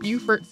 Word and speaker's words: Beaufort: So to very, very briefly recap part one, he was enslaved from birth in the McKee household of Beaufort, South Carolina, which is pronounced differently from Beaufort, North Carolina, even though they are both named after the same person.